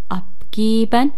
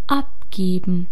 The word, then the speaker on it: abgeben: به تلفظ این واژه دقت کنید لطفاً. abgeben و اون صدای ا داخل پرانتز اومده، پس یعنی میتونیم تلفظش کنیم و میتونیم هم تلفظش نکنیم. abgeben. geben. وقتی که تلفظش نمی کنیم یه حالت تو دماغی پیدا می کنه.